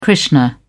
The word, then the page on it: krishna: British English